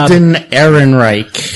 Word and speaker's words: Alden Ehrenreich: Alden Ehrenreich.